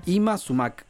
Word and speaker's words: Yma Sumac: Ima Sumac. Ima es el nombre. – Ima Sumac. – Se escribe con Yma Sumac.